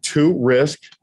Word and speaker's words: Torisk: My name is spelled to risk and my blood type is positive.